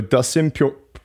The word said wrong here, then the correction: Poirier: See, now I can't say it right. Uh, Dustin Pure. Oh, God. I'm butchering this. His sauce, yeah. Poirier. There we go.